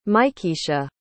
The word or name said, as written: mikiesha